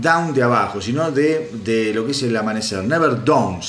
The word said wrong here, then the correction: Never Dawn: sería, ¿no? Never down. El, el Tano Rapanelli debe decir, si está escuchando este hijo de puta, qué mal que pronuncia. Bueno, yo no pronuncio muy bien ya saben, soy medio disléxico y, no pronuncio muy bien. Se llama Never Dawn.